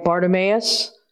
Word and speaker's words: Bartimaeus: All the way in the New Testament, there was a mention of Jericho and the guy Bartimaeus. Am I saying it right? Yes.